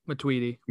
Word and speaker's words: Matuidi: Because if this happens to Matuidi, is that how you say it? – Matuidi. – Matuidi.